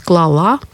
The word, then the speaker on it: клала: И вот только в них, в женском роде, в прошедшем времени, сохраняется ударение на основе. То есть клала, крала, слала, послала или слала.